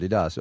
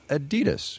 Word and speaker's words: Adidas: You've been saying Adidas, as in Adi Dassler, Adidas. Over here we say Adidas. Which one's right? – Oh, definitely the European pronunciation, and it's really strange. I really can't explain why you pronounce it differently in the US.